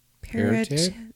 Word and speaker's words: parotoid: No. P-A-R-O-T-O-I-D. Parotid. – Spell it again. – P-A-R-O-T-O-I-D.